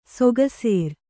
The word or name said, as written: ಸುಕೇಸರ